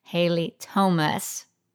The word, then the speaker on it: Haile Thomas: I am so excited to be here with Haile Thomas.